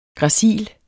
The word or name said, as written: gracil